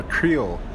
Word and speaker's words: Creel: Grill, G-R-I-L-L? – C-R-E-E-L. Creel, like... – It's C-R, Creel. – Yeah. – Got you. – The owner of the company pronounces it Creel.